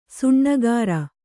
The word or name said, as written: ಸೋನಗಾರ